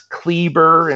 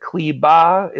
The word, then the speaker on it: Kleber: I can't stand when somebody calls Mo Wagner instead of Wagner, or Max Kleber instead of Kleba is the way it's pronounced.